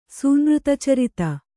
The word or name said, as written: ಸೂನೃತಚರಿತ